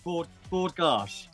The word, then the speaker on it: Bord Gáis: Now, I can never say it right. Bord Gáis. – Yeah, you've got it.